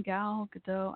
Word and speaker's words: Gal Gadot: How do you say her name? Gal Gadot.